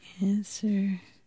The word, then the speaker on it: answer: Silent P, right? Or answer? – W.